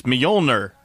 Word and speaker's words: Mjolnir: How do you say that hammer's name? Mjolnir. No. – It's Mjolnir. – Yeah, that's what I said.